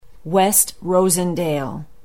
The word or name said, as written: west rosendale